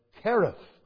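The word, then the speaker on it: Cherith: And that's the same with, if you take the river or the brook, Kereth, CH, is pronounced K.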